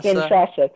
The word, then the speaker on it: Kinshasa: Can you help me pronounce this? – Is it Kinshasa? – Kinshasa, thank you.